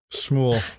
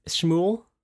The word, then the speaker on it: Shmuel: Shmuel Ellis. – Shmuel. – Dude, it's S. Shmuel? – Shmuel. – Shmuel like Ellis. S-H-M-U-E-L. – Shmuel. – It's a tough one.